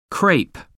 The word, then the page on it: crepe: American English